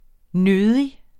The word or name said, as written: nødig